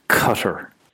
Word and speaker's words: Qatar: like Qatar, or Qatar, however you pronounce it, and always use the English pronunciation. – Yeah. Qatar.